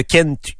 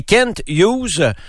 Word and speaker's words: Kent Hughes: cette embauche de Kent Hughes. Ça a duré 2 mois, grosso modo, le processus d'embauche. Et Kent Hughes, je fais exprès là, pour prononcer le T parce que c'est Kent et non Ken.